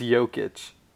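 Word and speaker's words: Jokić: It's Jokic. – Is that not right? – It's Jokic. Oh. That's how you pronounce it. I think he's Serbian, so that's how you say his name.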